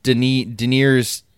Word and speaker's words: Daenerys: Daenerys Targaryen.